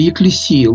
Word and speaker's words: екклесия: «Екклесия» или «екклесия» лучше, греки делают ударение на последний слог, и «кайнония» – община, общение.